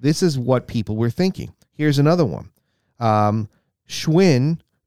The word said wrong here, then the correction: Schwilm: or I said that wrong, it's Schwilm, S-C-H-W-I-L-M, versus Pennsylvania Sports.